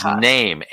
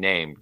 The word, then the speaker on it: Naim: Usually that's It was NAIM, N-A-I-M. Are you familiar with that? – Nope. – I'm probably saying it wrong. – Naim. – I'm saying name just because that's it looks like how.